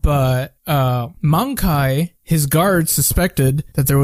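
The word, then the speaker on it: Möngke: M-O-N-G-K-E. Sounds like Mongkai.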